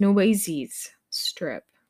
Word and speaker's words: Nueces Strip: The Nueces Strip. I'm gonna Google it, but it's gonna be a fun episode. Okay, so Google says that it's pronounced Nueces Strip, or at least the English pronunciation, Nueces Strip.